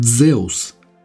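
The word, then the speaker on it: Дзеус (Ζεύς): Имя Зевс по-гречески произносится скорее как Дзеус и происходит от имени древнего общеевропейского бога неба.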